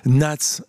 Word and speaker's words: Knats: Nats, K-N-A-T-S, on ne prononce pas le K, comme souvent en anglais.